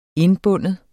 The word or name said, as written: indbinde